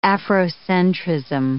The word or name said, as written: Afrocentrism